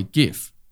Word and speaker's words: GIF: Oh, well, the correct way, GIF. Yeah, you don't. You clearly don't say it that say way. No. I'll be there in a jiffy. That's it. That's the only GIF.